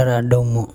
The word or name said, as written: araDumo